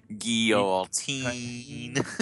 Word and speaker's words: guillotine: Guillotine. – Oh, yeah. – You gotta say it like you're from Louisiana. Guillotine.